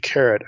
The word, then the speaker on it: Keret: So you ask, well, what in the world is the Karaite epic or the Kirta epic? You'll see it referred to both ways. Karaite is spelled K-E-R-E-T. Kirta is K-I-R-T-A.